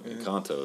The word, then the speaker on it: Encanto: Encanto, is how you say it? – Yeah.